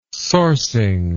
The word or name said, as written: sourcing